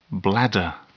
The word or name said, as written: bladder